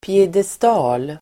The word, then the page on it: piedestal: piedest'a:l